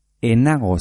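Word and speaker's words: ez nago: Ni enago zarautzen. Pero se escribe ni es nago zarautzen. Ni enago zarautzen. Se obvia la Z.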